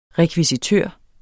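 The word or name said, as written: rekvisitør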